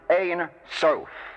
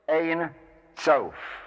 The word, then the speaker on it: Ein Sof: The second one is Ein Sof. Ein Sof. Ein Sof, which means literally without end, the infinite. And finally, there is Ein Sof Hour.